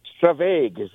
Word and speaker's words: Stravaig: Stravaig? How do you say it? – Stravaig. It's a great word. It's a very Scottish word, and it just means to go on a wander.